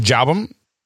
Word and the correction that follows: Jalbum: I'm pretty sure it's pronounced Jalbum.